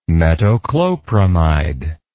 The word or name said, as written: metoclopramide